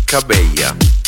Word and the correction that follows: Cabello: Che è Cabella? Il mix tra Camila e Cabello? – Si legge così. – Veramente è Camila Cabello.